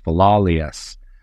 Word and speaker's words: Philolaus: I believe it's pronounced Philolaus.